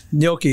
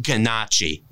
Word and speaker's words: gnocchi: And for reference, it's pronounced gnocchi, not ganache. – Not ganache, no. – No, at least when you're around someone Italian, you don't say ganache. Actually, when you're around anybody, it's called gnocchi.